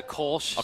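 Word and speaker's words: Kölsch: It's a Kolsch style ale.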